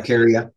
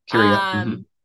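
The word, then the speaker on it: Carya: Is it pronounced Caria? Caria ovata? – Yeah, Caria. Uh-huh. – Caria.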